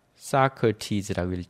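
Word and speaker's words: Socrates: 여러분, 소크라테스는 뭐라고 그래요? 사크티즈라고 읽죠? 아리스토텔레스는 어떻게 읽어요? 에리스타로. 그렇죠. 에리스타로, 플레이로 이렇게 읽어요.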